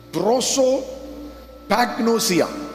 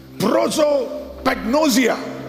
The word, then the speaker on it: Prosopagnosia: It is an affliction called Prosopagnosia. Prosopagnosia.